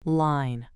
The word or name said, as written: line